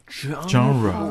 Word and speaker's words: genre: genre 哦，那发音很特别啊，genre。